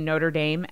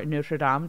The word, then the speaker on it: Notre Dame: a study that came out from a collaboration between Notre Dame and or Notre Dame, depending on how you want to pronounce that, if you're French or Midwestern, and Northwestern University, said that a tight circle of female friends is the number one indicator for a woman's professional success.